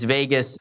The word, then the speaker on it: vagus: And is Vegas, it's not spelled like Las Vegas, just in case you're wondering. It's V-A-G-U-S.